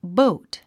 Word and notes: boat: bóut; ボート

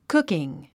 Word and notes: cooking: kúkiŋ; クッキン

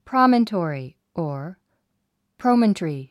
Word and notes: promontory: prɑ’məntɔ`; プロメントリィ

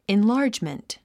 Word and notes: enlargement: inlάːrdʒmənt; インラァジメント

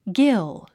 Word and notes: gill: dʒíl; ギル

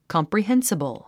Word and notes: comprehensible: kὰmprihénsəbl; コォンプリヘンスィボゥ

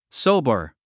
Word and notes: sober: sóubə r; ソゥバァ

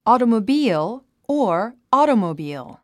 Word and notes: automobile: ɔ`ːtəməbíːl; オータモ ビール

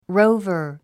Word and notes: rover: róuvə r; ロォバァ

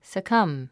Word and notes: succumb: səkʌ́m; サカァム

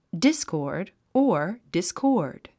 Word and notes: discord: dískɔː r d; ディスコード

